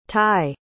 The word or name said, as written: thai